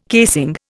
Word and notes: casing: Indian

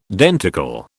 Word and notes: denticle: British English

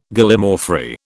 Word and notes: Gallimaufry: British English

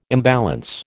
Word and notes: imbalance: American English

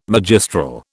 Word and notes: magistral: British English

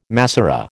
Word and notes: Massorah: American English

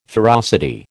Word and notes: ferocity: American English

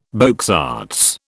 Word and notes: beaux arts: British English

